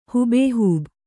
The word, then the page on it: ಹೂಬೇಹೂಬ: hūbēhūba